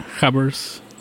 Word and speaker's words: gabber: But the line was called like the Australian tracksuit, and that was like a status symbol, because there were many working class Gabbers. That's how they pronounce it in like Dutch, Gabber.